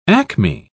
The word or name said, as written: acme